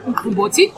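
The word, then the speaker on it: umqombothi: Women in South Africa would brew mkomboti, let me just say it correctly. There's a click there, mkomboti, which is a sorghum and maize beer.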